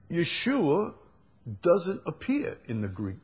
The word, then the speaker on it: Yeshua: You use Yeshua. You use the Hebrew pronunciation. Now, I don't have a problem with, you know, Yeshua.